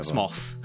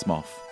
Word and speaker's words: Smough: People fight over whether it's pronounced smo or smow or, or, or whatever. – Smoff. yes, no, legit. I think it's smoff because.